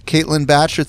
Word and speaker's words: Caitlin Bacher: C-A-I-T-L-I-N-B-A-C-H-E-R. And if you didn't catch that, we'll have it in the show notes. Caitlin Batcher, thank you so much for coming on and sharing your awesome wisdom with us.